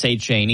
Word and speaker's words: Etienne: The players surrounding him are Travis Etienne, who now goes by Travis Etienne. He corrected everybody on how his name's pronounced.